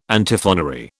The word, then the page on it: Antiphonary: British English